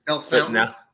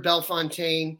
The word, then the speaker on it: Bellefontaine: Bellefontaine. – Yeah, is that how you say it? I've never heard anybody say it out loud. – I think it's Bellefontaine. I've heard Bellefontaine.